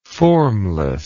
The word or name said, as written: formlessness